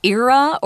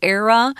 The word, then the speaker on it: era: Now, before we move on to this, let's look look at this word era or era. You can pronounce it either way.